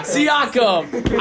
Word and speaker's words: Siakam: And Siakam.